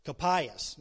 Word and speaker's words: Caiaphas: I think I pronounced that right, but Caiaphas. Caiaphas, there you go.